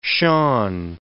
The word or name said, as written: Shan